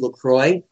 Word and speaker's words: Lacroix: That is LACROIX, or Lacroix in French. – Lacroix is the main pronunciation, is that right? – It's I guess you could call it, you can say Lacroix or Lacroix. It doesn't really matter. They're both ways to say it, but it's fine either way.